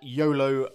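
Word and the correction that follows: Xolo: Maraduena. Maraduena. – His name is Sholo. – Sholo. – Maraduena. – There you go.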